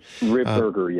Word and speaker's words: Ripperger: How do you say his name? Do you know, is it Ripinger? It's Ripinger, right? Father Chad Ripinger, I'm pretty sure. – Ripberger, yeah. – Ripberger, thank you very much.